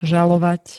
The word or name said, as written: žalovať